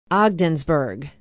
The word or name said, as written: ogdensburg